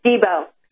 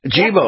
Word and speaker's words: Jibo: It's Jibo. – Jibo. – J-I-B-O.